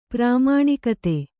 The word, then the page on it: ಪ್ರಾಮಾಣಿಕತೆ: prāmāṇikate